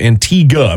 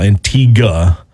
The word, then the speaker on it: Antigua: Antigua, by the way, is how you say it. I had to learn that after a while. Antigua.